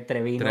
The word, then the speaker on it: Treviño: Bueno, sí, porque es con Ñ, pero es que se escribe Treviño.